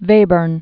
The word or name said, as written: Webern